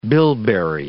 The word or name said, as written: bilberry